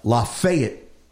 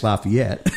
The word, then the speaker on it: Lafayette: It's there's Lafayette, Tennessee. Now up in Cincinnati, it's Lafayette.